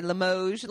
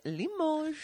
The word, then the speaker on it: Limoges: We found out we were saying it wrong for the first bit of time. We'd say Limoges. And it was like, no, it's Limoges.